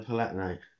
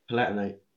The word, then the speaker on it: Palatinate: The Palatinate. – Yeah. Is that how you say it? – I think so. Okay. The like Austro-Hungarian area. – Yeah. Okay, say it again. – Palatinate.